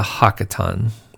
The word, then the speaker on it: haqqaton: He replies that there remains yet the hakaton. That's spelled H-A-Q-Q-A-T-O-N, the hakaton.